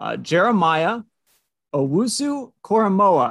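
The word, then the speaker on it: Owusu-Koramoah: More and more mocks seem to show Jeremiah Owusu-Koramoa. I'm trying to get that name right. – That was actually right. That's yes. How you pronounce it. So well done.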